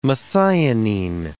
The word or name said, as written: methionine